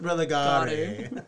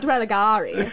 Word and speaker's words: religare: It's pronounced religare. – Not religare.